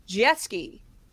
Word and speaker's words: Jeske: Juliet Jeske. – Which is spelled what? – J-U-L-I-E-T, like Romeo and Juliet, and J-E-S-K-E. It's Prussian. Jeske. – Oh. – Not French. Jeske.